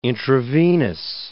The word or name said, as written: intravenous